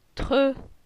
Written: Tre

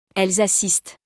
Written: elles assistent

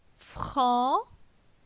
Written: Fran

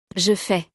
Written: je fais

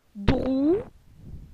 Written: Drou

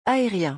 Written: aerien

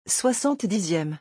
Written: 70eme

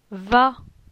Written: Va

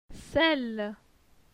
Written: Celle

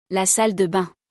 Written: La salle de bains

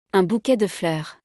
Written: Un bouquet de fleurs

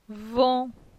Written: Von

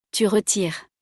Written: tu retires

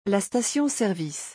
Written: la station-service

